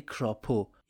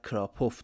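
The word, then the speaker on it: Kropow: کراپو یا با تلفظ لهستانی شاید کراپوف درست باشه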